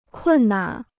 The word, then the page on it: 困难: kùn nan